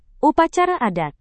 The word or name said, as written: upacara adat